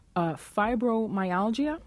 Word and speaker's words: fibromyalgia: Anitha, you've got a story about fibromyalgia, am I pronouncing it properly? – Yes, yes.